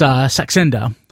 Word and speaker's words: Saxenda: Let me make sure I get the pronunciation correct because people mispronounce it all the time. It's Saxenda, which is a 3-milligram injection.